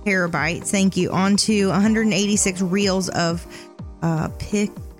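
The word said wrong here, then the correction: Piql: film. – I think it's pickle film. Yeah. – Just for the listeners out there, it's spelled P-I-Q-L film.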